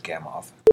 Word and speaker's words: Gamow: I learned immediately after this aired that his name is pronounced Gamow.